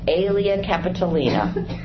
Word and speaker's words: Aelia Capitolina: He renamed Jerusalem Aelia Capitolina. I always say that wrong. Aelia Capitolina.